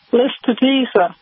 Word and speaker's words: Lespedeza: P-E-D-E-Z-A. And it works great. And the cows love it. And back to you. – Lespedeza, did you say? How do you say that? – L-E-S-P-E-D-E-Z-A. Lespedeza.